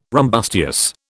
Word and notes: rumbustious: British English

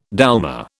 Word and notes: dolma: British English

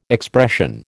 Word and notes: expression: American English

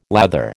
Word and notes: lather: American English

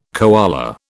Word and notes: koala: British English

